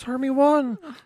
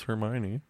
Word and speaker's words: Hermione: He's like, I thought it was Hermie 1.